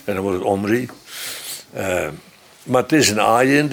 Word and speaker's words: Omri: En Omri heet daar Gomri. Maar als die ayin wegvalt, dat is naar het gebruikelijke moderne Hebreeuws, ook Bijbels Hebreeuws zoals men het opvat, daar spreek je de ayin niet eens meer uit. Sla je gewoon over. Dus dan wordt het, omdat er toch een klinker moet komen, schrijft men Omri.